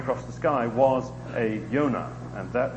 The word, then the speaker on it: Yonah: The key character, his name, of course, is Jonah, or pronounced in the Hebrew, Yonah. The English, to my knowledge, are the only ones who pronounce the letter J with the sound that they do. So in most of the rest of the languages of the world, Yonah would be pronounced.